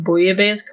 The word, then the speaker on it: bouillabaisse: First off, you did actually pronounce bouillabaisse correctly.